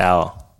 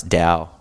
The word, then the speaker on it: Tao: The T is silent, I think, yeah. – Oh, is it Ao? – No, it's Tao.